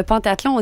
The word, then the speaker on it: pentathlon: On dit, est un peu, moi j'avais l'habitude aussi de dire le pentathlon, on dit pentathlon. – Pentathlon. – C'est bien mentionné. – Pentathlon. – Mentionné pain comme le pain, P-A-I-N, tathlon.